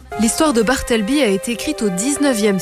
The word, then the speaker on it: Bartleby: Ça s'appelle Bartleby, prononce. Ça s'appelle Bartleby.